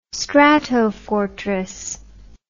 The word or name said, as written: Stratofortress